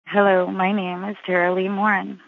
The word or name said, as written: Tara Lee Morin